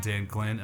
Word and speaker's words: Dan Glynn: Well, okay, so my guests today are Gabe Romero and Dan Glynn.